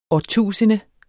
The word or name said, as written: årtusinde